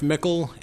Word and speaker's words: Michel: Sheriff Mickle, M-I-C-H-E-L, Wetzel, W-E-T-Z-E-L.